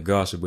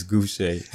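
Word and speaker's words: Gausha: Sorry, last time I was pronouncing Gouche, but it's Gouche.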